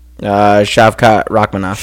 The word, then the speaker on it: Shavkat Rakhmonov: Shavkat Rakhmonov. – Shavkat Rakhmonov.